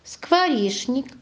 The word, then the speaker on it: скворечник: Скворечник, что, конечно, скучный, чтобы. Посмотрите, можете посмотреть словарики. Но запомните. Скворечник, что, конечно, скучный, чтобы. Мы пишем согласно «ч», а говорим «скворечник». Что, конечно, а произносим «ш».